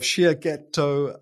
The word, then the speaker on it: Shiageto: And you are the founder and CEO of Shia Ghetto Consulting.